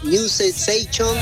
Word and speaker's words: New Sensation: Bueno, mi inglés no es muy bueno, pero es New Sensation. – Está bien, bien pronunciado.